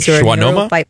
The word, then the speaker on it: schwannoma: And it turned out to be a nerve tumor on the inside, and he called it like a shashwoma or something. I don't know what it is. – Schwannoma? – Schwannoma? Is that how you say it? – Yeah, that I've got one of those.